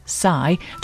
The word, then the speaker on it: Psy: So much so that I've learned the dance, and I'm desperately trying to pass on the legend that is Psy, pronounced Psy, to all of my friends, but they're having none of it.